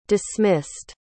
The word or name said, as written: dismissed